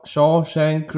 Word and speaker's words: Shawshank: Oder der äh, Originaltitel, habe ich gesehen, The Shaw, Shawshank Redemption.